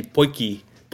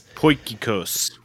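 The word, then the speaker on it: potjiekos: And do you know how to pronounce the, is it potjikos? – It's a tough one. It's actually poikikos. – Poikikos.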